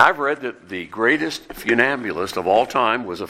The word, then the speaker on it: funambulist: Who knows what see if I can pronounce this correctly, funambulist is. A funambulist. That's spelled F-U-N-A-M-B-U-L-I-S-T.